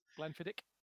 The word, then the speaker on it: Glenfiddich: Glenfiddich. – Glenfiddich. – Hard K on the end of that. – Yeah. Well, and the tour guide made a contest about if we could pronounce the name of Glenfiddich.